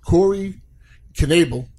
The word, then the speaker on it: Corey Knebel: Corey Knebel.